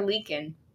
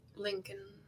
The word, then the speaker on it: Linkin Park: Is it pronounced Lincoln or Leakin? – Lincoln. – Okay. – Lincoln Park.